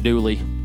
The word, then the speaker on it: Dula: His real name was Tom Doula, D-U-L-A, but they just kind of pronounced it Dooley.